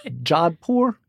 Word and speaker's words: Jodhpur: No, I'm J-O-D-H-P-U-R.